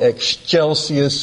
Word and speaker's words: Excelsis: The first category would be Latin phrases, a Latin phrase that we sing in the song, "Angels We Have Heard on High, Gloria in Excelsis," or Excelsis, some people say, which is the Italian pronunciation.